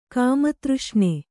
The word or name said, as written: ಕಾಮತೃಷ್ಣೆ